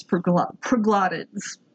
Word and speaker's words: proglottids: So it's P-R-O-G-L-O-T-T-I-D-S. Proglotes. That's how it's pronounced.